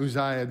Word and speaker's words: Uzziah: It says, in the year that King Uzziah died, or Uzziah, depending on how you say it, died, I saw the Lord high and exalted, seated on the throne, and the train of his robe filled the temple.